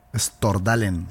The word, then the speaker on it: Stordalen: Peter Substrolan. – Petter es P-E-T-T-E-R. Stordalen es S-T-O-R-D-A-L-E-N. Stordalen. Stordalen, sí.